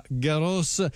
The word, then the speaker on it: Garros: Seguiremo questo Gran Premio insieme, c'è anche l'attesa della finale del Roland Garros e la S va pronunciata, mi raccomando, colleghi italiani d'Italia, non si pronuncia Roland Garros ma Garros, e non c'è Yannick Sinner, però c'è un altro italiano in finale, dunque seguiremo anche questa prestigiosa competizione.